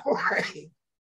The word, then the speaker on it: Jorge: Jorge. – Yeah, okay, yeah. Yeah. Jorge.